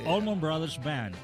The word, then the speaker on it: Allman Brothers Band: Pronuncia bien, porque luego... – Allman Brothers Band. – Eso.